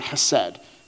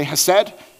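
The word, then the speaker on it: hesed (חסד): And this word kindness in the Hebrew is this word hesed. Can you guys say hesed? Hesed. Awesome. So it's H-E-S-E-D, even that's not how you spell it because it's Hebrew and there's a lot of different vowels and things.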